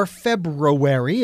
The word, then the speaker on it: February: So, before we dive into World Sound Healing Day, which, by the way, folks, is on the 14th of February, or February, if you choose to pronounce that R in there,